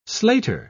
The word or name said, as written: slater